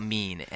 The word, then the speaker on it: Mene: Yeah, I mean, they opened with Mean, or we'll call it Mean, M-E-N-E. – We're going to do Mean today. – We're calling it Mean. Rhymes with meme.